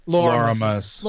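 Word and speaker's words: Loramus: I think that with characters like Lorathmus? How do you pronounce his name? – Lorimus. – Lorimus, thank you.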